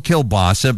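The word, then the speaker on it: kielbasa: We grew up calling Kilbasi, Kilbasi. I know it's spelled Kilbasa, but we always said Kilbasi, and I know a lot of people do. But I was dictating into my phone, telling somebody about the Kilbasi Festival, and looked down, and it's spelled a Kilbasa. So maybe I am wrong.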